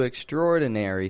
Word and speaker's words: extraordinary: And that word extraordinary, most students, all people from all over the world, when they read the word extraordinary, because it's spelled extraordinary, people will often pronounce the A in there, extraordinary. Now, that's like, Frank Sinatra, he pronounces it extraordinary. I, however, do not pronounce it extraordinary. I just say extraordinary.